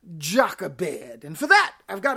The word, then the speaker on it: Jochebed: Jochebed is the English pronunciation of it. The Hebrew would be Jochebed, Jochebed.